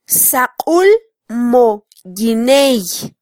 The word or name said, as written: saqul mo yiney